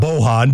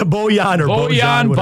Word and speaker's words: Bojan: Yeah, on it. Bojan. Not Bohan, but yeah, Bojan Bogdanovic, who is, I guess, a really good player.